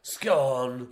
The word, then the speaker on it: scone: Scone. – Isn't the joke that it's gone? – No. That's not the joke. Which is also why it's pronounced scone and not scone, by the way.